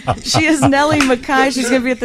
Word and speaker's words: McKay: That's how it's spelled, M-C-K-A-Y. But pronounce Mackay.